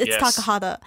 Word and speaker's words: Takahata: It's Takahata.